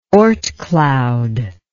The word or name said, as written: Oort cloud